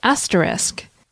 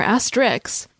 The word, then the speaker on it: asterisk: It's pronounced asterisk. Asterisk. It's common to hear people call it asterisk or asterix, but the correct pronunciation is asterisk.